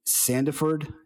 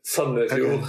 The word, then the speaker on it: Sandefjord: so, um, as I said, lives in Norway, in Sandefjord, Norway. Did I say that right? – Uh, almost, yeah. Sandefjord. – Okay. – It's oh, there you go.